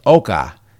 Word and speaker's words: ochre: Ochre pronounced Oka.